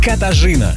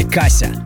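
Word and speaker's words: Katarzyna: Ее зовут Катажина. Катажина, а проще Каша. Но чаще ее имя произносит на английский манер Кассия или Кася.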